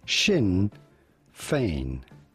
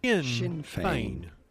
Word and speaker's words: Sinn Féin: We are looking at how to pronounce Sinn Féin. – Sinn Féin.